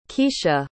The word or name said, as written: keesha